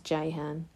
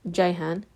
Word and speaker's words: Ceyhan: And yes, it is spelled with a C despite the fact that it's pronounced Jehan. It's a Turkish name and my husband's dad is Turkish and people in Turkey pronounce their C's as J's. So it's Jehan, faye.jehan.